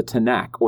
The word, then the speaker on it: Tanakh: And that order is symbolized by an acronym that we would pronounce the Tanakh or the Tanakh. And this is T, N, and K with two A's in between to kind of make it a word.